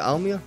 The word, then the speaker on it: Almere: Charlie Gilmore, Telstar 1-1-0 against. That's two wins on the bounce for them against Almere. – Almere. – Is it Almere? – Yeah.